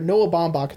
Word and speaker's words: Baumbach: Noah Baumbach, that's how you pronounce his name, by the way.